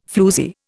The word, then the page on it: floozie: Indian